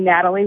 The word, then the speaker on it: Natalie: Unfortunately, my name Natalie rhymes with Fatally, so I have memories of that.